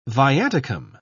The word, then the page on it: viaticum: British English